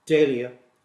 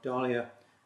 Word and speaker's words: dahlia: And the flower we pronounce dahlia, not dahlia, is the sacred flower of the Aztecs.